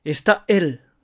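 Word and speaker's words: está él: ただしエスタとエルの間に喉を詰めてエスタエルなどと発音しないようにしてくださいね。あくまでもエスタエル滑らかに発音し、なおかつ両方ともはっきり発音するということが重要です。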